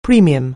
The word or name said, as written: premium